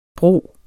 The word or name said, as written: bro